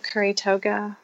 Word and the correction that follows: Kuru Toga: Mm-hmm. Kuru toga. Kuru, kuru toga.